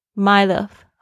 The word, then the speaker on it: might have: 那我们要特别注意一下，是 might have 的发音，常常会很快的念的时候是 might have，might have。 My love 啊，大家记住，所以你听的时候呢，会听到这个讲的时候，你可能讲的还不是很顺，但是呢，一定要听懂。